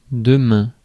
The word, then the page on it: demain: France (Paris); [dø.mæ̃]